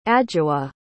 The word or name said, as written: adwoa